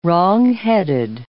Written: wrongheaded

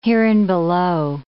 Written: hereinbelow

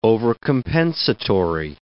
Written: overcompensatory

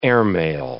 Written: airmail

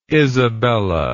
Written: isabela

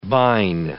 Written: bine

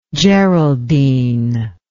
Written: geraldine

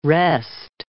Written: wrest

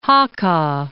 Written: Hacha

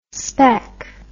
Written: spek

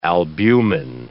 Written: albumen

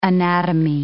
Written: anatomy

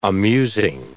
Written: amusing

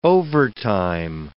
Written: overtime

